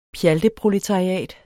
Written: PJALTEPROLETARIATS

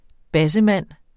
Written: bassemand

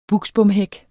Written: buksbomhæk

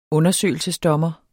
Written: undersøgelse

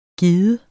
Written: gide

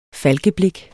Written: falkeblik

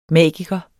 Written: magiker

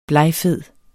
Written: blegfed

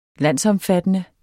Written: landsomfattende

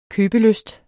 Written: købelysten

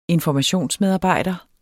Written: informationsmedarbejder

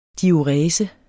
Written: diurese

